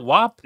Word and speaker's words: WAP: We have to talk about the WAP. Is that how you say it? WAP? – Yeah, no, you got it right.